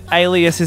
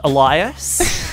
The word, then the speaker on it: alias: Did you just think that alias is pronounced Elias? – That's what I meant to say.